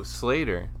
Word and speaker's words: Sleater: First off, we are going to have Sleater Kenny. I always have a hard time pronouncing the name because it's spelled... Oh, Slater.